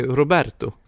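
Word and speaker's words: Roberto Benigni: Uttalas det Robert Benigni? Nej, Roberto.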